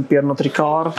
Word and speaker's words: Pernod Ricard: Jasně, tak ty máš porovnání s tím, ty já no, nevyslovím Pernod Ricard.